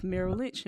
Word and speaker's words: Merrill Lynch: and then also basically her background with Merrill Lynch.